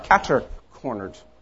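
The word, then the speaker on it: catercornered: And actually, it's catter, C-A-T-E-R, catter-cornered. Not the way I grew up pronouncing it, but still. And again, it's not an American phrase at all. It's French, catter from quatre. Is that right? Quatre, meaning four-cornered.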